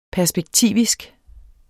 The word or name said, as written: perspektivisk